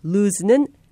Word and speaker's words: lose: 발음할 때 L-O-O-S-E, lose는 S로 끝납니다. 반면에 L-O-S-E, lose는 Z로 마무리합니다. lose.